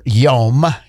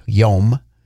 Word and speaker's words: yom: The Hebrew word here, yom, is the Hebrew word. That's how you pronounce it. – Is that the day? – Day. – Okay. – For day, yom.